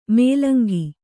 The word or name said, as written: ಮೂಲಂಗಿ